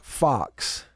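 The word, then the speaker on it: Fuchs: Daniel on YouTube is telling me that Florida's president's last name is pronounced Fox. Is it? I mean, it's spelled F-U-C-H-S. I mean, you know, look, if you're going to spell it that way and it's pronounced like Fox, like the little animal running around the yard, then more power to him.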